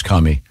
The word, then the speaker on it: Kumi: I believe it's pronounced Kumi.